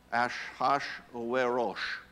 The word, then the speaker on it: Ahasuerus: only if you were speaking in Hebrew, it would be pronounced Ahasuerus.